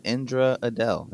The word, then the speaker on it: Indra Adelle: Her name is Indra, and she goes by Indra Adele. Is that correct? – That is correct.